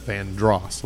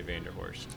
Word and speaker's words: Vanderhorst: Which is spelled Vanderhorst. So when you see Vanderhorst, it's actually pronounced Vandross, like Luther.